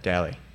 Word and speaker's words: Dally: How you say your last name? Dally? – Dally. Yeah. – Oh, no shit. – You've been saying it wrong the whole time, but I'm saying daily.